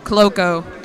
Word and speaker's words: Cloco: Again, Kat, how do you say your last name again? – Cloco. Like a running cloak.